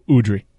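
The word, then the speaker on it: Udrih: I never know how to pronounce his name. Is it Udre? Udro? – Beno Udre. – Mike nailed it.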